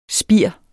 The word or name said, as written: spir